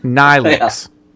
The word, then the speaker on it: Neelix: Neelix, pronounced Nylix.